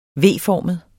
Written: V-formet

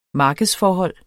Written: markedsforhold

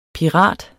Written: PIRATEN

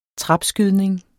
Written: trapskydning

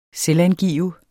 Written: selvangive

